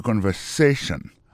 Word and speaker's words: conversation: Mot conversation, accent tombé sur avant-dernier syllabe là. You know? Listen to the conversation.